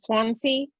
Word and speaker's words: Swansea: How do you pronounce Swansea? – Swansea, yeah.